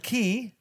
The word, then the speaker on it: Key: And key rhymes with flea. Yeah. Flea.